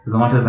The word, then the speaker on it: Zomato: have actually said that Zomato's IPO is, I'd say, a blockbuster. – Why? Apart from the fact that Zomato rhymes with tomato.